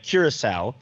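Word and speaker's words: Curaçao: I believe the correct pronunciation is Curacao.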